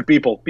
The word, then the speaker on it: Beeple: I just literally when that guy Beepo I know I've said his name wrong, but when it looks like Beeple.